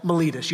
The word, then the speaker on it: Miletus: And so they stop off at a little port called Miletus. You don't have to remember that. I did look it up to make sure I was saying it right, because that's not how I would have pronounced it.